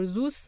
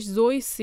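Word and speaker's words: Seuss: تلفظ اسم دکتر سوس شک و شبهه توش زیاده یه سری میگن دکتر سوس یه سری میگن دکتر زوس و یه عده هم میگن در واقع تلفظ درستش زویس یا سویسه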